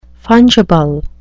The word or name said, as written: fungible